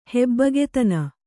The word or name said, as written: ಹೆಬ್ಬಗೆತನ